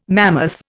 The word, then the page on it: mammoth: Indian